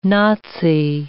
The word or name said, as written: Nazi